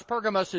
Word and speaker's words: Pergamos: If we go to Pergamum or Pergamos, Pergamos is the more modern Greek way of pronouncing Greek word for the city.